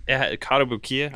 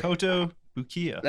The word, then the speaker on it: Kotobukiya: How do you say Kotobukiya? I never. – Kotobukiya. – That's what I always say. Kotobukiya. – I don't want to say Bukkake, and it's very close to that. – Kotobukiya.